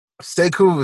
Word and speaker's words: Cequo: Sayku was actually my middle name, but it's spelled C-E-Q-U-O, and that was just too hard for people to spell.